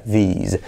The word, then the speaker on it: these: Luego, acuérdate de diferenciar entre I corta, E, y I larga, these, y luego entre S sorda, que sería this, y luego S sonora, que sería these.